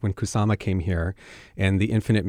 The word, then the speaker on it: Hir: Here by Taylor Mack, that's at Arts West. – H-I-R. – H-I-R, pronounced here. It's a play on him and her.